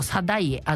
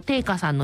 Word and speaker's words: 定家: あと定家、どっちでもいいんだそうです、読み方は。藤原の定家さんの日記に、文暦 2 年 5 月 27 日、自分が選定した小倉百人一首が完成した。